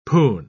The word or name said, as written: poon